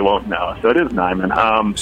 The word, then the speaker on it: Niman: Yeah, first, and this happens often, the first thing I'll need to do is correct pronunciation. It's actually Neiman.